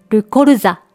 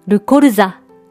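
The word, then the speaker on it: colza: これは男性名詞で、ルコルザ、ルコルザと言います。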